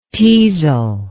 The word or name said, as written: teazel